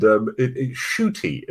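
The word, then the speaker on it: Ncuti: And Shooty is how you pronounce the name.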